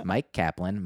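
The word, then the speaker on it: Myq Kaplan: If anyone asks you to plug me, my name is Mike Kaplan.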